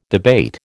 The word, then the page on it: debate: American English